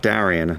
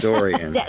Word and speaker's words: Dorian: Not Darian, not Doriax. Dorian.